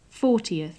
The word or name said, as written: fortieth